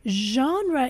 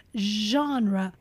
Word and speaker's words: genre: Genre is our first English noun in today's episode. Genre. Pay attention to the pronunciation. Genre. Genre. Genre means type, kind, or category.